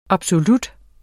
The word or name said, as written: absolut